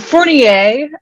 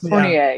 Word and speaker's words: Fournier: Fournier, actually. – Okay. – The A is the only French part we've retained, but Fournier, yeah.